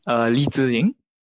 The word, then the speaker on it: Li Ziling: So it is Li Ziling, which is Chinese, but usually for people that can't pronounce it well, I just tell them to call me Zing, like the metal.